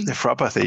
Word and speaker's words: nephropathy: The reversal of diabetic, how do you pronounce that? Nephropathy? – Nephropathy, yeah.